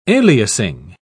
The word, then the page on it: aliasing: British English